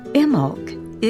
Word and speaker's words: Imbolc: Spelled I-M-B-O-L-C, but the B is silent. Imolk is just a little uplifting thing that anyone can take part in.